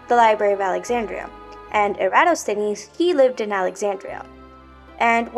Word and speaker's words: Eratosthenes: But have you ever heard of Eratosthenes? I know his name is pretty hard to pronounce, but I want y'all to say it with me. So, Eratosthenes. So I guess it has like five syllables, but did you get it?